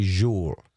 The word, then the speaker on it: Joel: Not Joel. We don't say Joel. – No, not Joel. It's spelled Joel, but he's Swedish, so we say Joel.